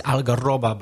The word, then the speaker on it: algarroba: Carob is algarroba, by the way.